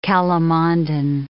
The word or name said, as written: calamondin